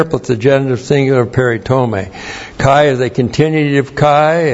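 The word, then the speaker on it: Peritome (περιτομή): P-E-R-I-T-O-M long E. Peritome.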